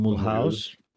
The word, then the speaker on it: Mulhouse: uh, não sei bem como é que se pronuncia. É Muluz, é capaz de ser Muluz. Eu estava aqui, a, estava aqui a, agora é como, é como Toulouse.